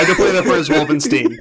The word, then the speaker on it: Wolfenstein: It's pronounced Wolfenstein.